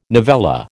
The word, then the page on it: novella: American English